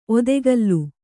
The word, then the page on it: ಒತ್ತುಗಲ್ಲು: ottugallu